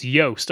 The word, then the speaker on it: Joost: Oh, yeah, we love his name rhymes with toast. It's Joost. I've been told Joost.